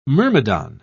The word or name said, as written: myrmidon